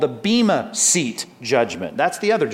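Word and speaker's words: Bema (βῆμα): Let's go to 2 Corinthians 5, but it says in verse 10, we must all appear before, here's our word, Bema. Those next two words, judgment seat, translates one Greek word, B, long E-M-A, Bema.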